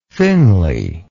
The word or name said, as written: finley